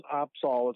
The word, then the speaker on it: Opsahl: It's Kevin and Courtney, and then the last name is Opsahl.